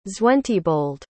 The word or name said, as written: zwentibold